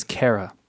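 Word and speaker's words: Cera: Michael Cirka. – It's Kara. – Oh, right. I thought he was putting the hard C in the middle.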